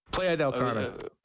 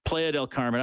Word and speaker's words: Playa del Carmen: He says, I once listened to your show while I was in, is it Playa del Carmen? Is that how you say it? – Playa del Carmen. – Playa del Carmen.